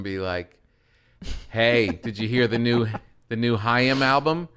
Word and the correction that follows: Haim: She's like, "It's Haim." Yeah. It's good though, right? Like, I was so close. I knew the album, I knew the song, and I mispronounced the name by one syllable.